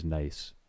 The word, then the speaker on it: gneiss: And it was talking about the article was about the differences between gneiss and granite, or gneiss and granite. And the joke only really works if you pronounce nice as nice.